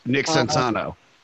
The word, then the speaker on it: Sansano: Nick Sansano. – Yes. – Okay, guys said it right, yes.